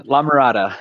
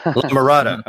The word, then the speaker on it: La Mirada: La Mirada. – La Mirada.